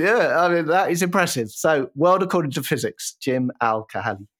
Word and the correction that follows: Al-Khalili: So I'm pretty sure that I'm right in saying you're butchering his name, and it's Jim Al-Khalili from Radio 4, okay.